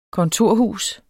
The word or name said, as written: kontorhus